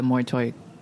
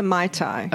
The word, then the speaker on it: Muay Thai: Very close to Mai Thai.